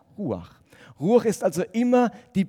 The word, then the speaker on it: Ruach: Aber der Hebräer, wenn er das Wort Ruach ausgesprochen hat, dann hat das nämlich etwas nachgemacht, nämlich so, so, Ruach oder andersrum, Ruach, Ruach, Ruach. Das Wort Ruach sollte den Atem, bewegte Luft laut malen.